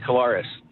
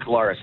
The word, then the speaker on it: Claris: Yeah, you know, my Yaya, which is Greek for grandmother, she always just said it was Claros. So we always pronounce it Claris like that.